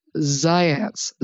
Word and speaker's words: Zajonc: If I say his name, I'll have to spell it for everybody, because I believe it was pronounced Zients, but I think he was Yugoslavian or something. And it's actually spelled Zajonc, believe it. It looks like Zajonc, I think, but it's pronounced Zients.